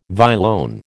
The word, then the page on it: violone: American English